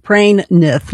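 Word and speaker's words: Praimnath: Stanley Praying Myth. Praying Nith, let me say that right.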